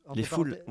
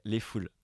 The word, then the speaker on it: Les Foules: Les Foules, ouais. – Ouais. Mais comme ça, entre parenthèses, on le prononce ou pas? – Bah ouais, du coup, c'est une sorte de jeu de mots... Ouais, Les Foules.